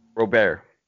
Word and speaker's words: Robert: At number eight, I have Luis Roberts. How would you pronounce that? – Robert. – Yeah. Like, almost like Gobert.